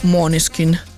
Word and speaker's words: Måneskin: Avevo scoperto tra l'altro che si legge tipo Måneskin nel modo giusto, tipo Måneskin, ma quello è sì, ma guarda, penso di dire tanti di quegli strafalcioni di certi nomi, di certe cose che vabbè, sorvoliamo.